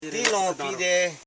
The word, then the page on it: longki: long.ki; Koro